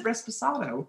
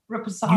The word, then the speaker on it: Reposado: You said, you did say it right. – I said it correctly, yeah. – you said it, yeah. – You said Reposado. – What is it? – Reposado.